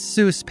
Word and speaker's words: Seuss: Hans's last name was spelled S-U-E-S-S. The other doctor's name was spelled S-E-U-S-S, with the E and U flipped. Both, though, were pronounced the same way. Seuss.